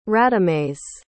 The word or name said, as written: radamés